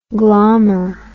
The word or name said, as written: glommer